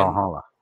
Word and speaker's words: Valhalla: Valhalla.